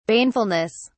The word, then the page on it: banefulness: British English